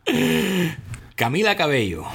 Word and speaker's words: Camila Cabello: Camila Cabello.